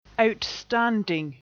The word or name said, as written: outstanding